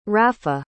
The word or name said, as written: rapha